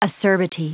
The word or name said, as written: acerbity